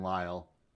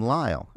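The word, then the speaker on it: Lisle: I would assume that's Katie Kyle in Lyle. – Yep. – In Lyle. – Okay. That's how you know. It's nice. Katie Kyle, and she rhymes with Lyle.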